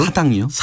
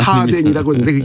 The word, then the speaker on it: 사댕이: 사당이요? – 사댕이. – 사댕이. – 예, 제 발음이 안 좋습니다. 사댕이라고 했는데 그게 살덩이의 호남 사투리라는 거예요.